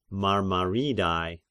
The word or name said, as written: Marmaridai